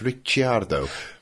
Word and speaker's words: Ricciardo: CI in Italian is pronounced CH, so it's Ricciardo.